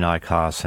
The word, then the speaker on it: IQOS: It is called IQOS, I-Q-O-S, am I saying that properly?